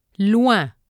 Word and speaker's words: loin: OIN is pronounced as WAN. – Loin. Besoin. Le son UI.